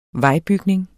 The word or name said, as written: vejbygning